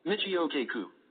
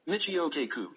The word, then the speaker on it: Michio Kaku: Michio Kaku. – Yes. One more time. – Michio Kaku.